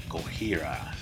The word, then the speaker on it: Gojira: I think that's how they pronounce their name, which actually they were originally called Godzilla, but had to change that for legal reasons. And so they adopted the Japanese version pronunciation, I guess you could say, Gojira.